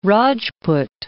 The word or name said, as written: Rajpoot